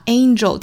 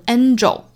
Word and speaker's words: angel: 很多人会把 angel 天使这个词发成 angel，一定注意哈，这是错误的发音。这个词它其实前面这个字母 a 呢，对应的是双元音 a 的发音，再跟后面鼻音组合发 ain ain ain， angel, angel 才对，一定注意不是 angel 哈，是 angel, angel。